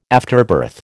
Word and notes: afterbirth: American English

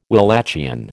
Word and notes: Walachian: American English

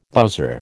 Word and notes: bowser: American English

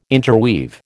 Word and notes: interweave: American English

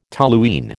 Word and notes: toluene: American English

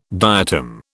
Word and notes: diatom: British English

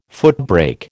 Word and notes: footbrake: American English

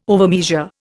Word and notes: overmeasure: Indian

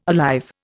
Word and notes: alive: Indian

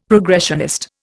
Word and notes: progressionist: Indian

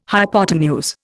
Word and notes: hypotenuse: Indian

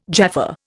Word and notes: Jaffa: Indian

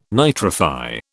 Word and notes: nitrify: British English

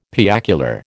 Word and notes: piacular: American English

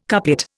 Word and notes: couplet: Indian

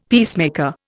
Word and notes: peacemaker: Indian

